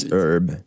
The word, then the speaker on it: herb: Is a herb? – It's herb. Herb. Herbs and spices. – Herb. – Yeah. Why do English words have so many silent letters? – Weren't you the English teacher?